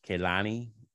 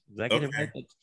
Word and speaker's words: Kehlani: Kehlani. – Kehlani.